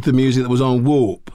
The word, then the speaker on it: Warp: W-A-R-P? – Warp. – Like the floor is warped.